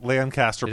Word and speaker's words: Lancaster: Lancaster, or is it Lancaster? How do you pronounce that one? – Lancaster, Pennsylvania. – Perfect.